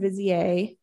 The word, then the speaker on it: vizier: According to one story, an Ottoman Grand Vizier, vizier, how do you say that? V-I-Z-I-E-R. – I think either way that you just said it is fine. – Great. – Depending on whether or not you're French.